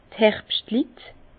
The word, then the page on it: d' Herbschtlit: Bas-Rhin; Herrlisheim; pl.